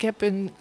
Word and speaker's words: Hebe: Maar wat ik mooi vind en wat volgens mij heel goed klopt is Hebe. Dus een beetje be en een beetje he.